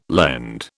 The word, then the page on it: lend: British English